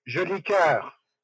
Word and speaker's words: Jolicoeur: Jolicoeur.